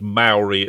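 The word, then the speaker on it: Māori: The word Maori itself has got a macron on the A, so for a long A.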